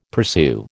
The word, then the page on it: pursue: American English